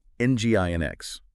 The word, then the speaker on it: NGINX: Today, we're going on a deep dive into the world of web servers, specifically NGINX, pronounced Nginx and traffic, pronounced traffic.